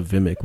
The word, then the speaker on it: Vimic: He's with Vimic or something now. His new band is called Vimic. Because I have Satellite, and he was on there promoting it. So what is a Vimic? What is, how do you spell that? – V-I-M-I-C.